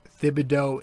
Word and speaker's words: Thibodeau: Is it Thibodeau? Is it Thibs. No, it's Thibodeau and Tibbs, I'm pretty sure.